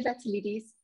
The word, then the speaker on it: Vatalidis: Yeah, it's Nadia Vatilidis.